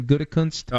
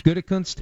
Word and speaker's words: Gutekunst: Gutekunst. – Gutekunst. – Gutekunst.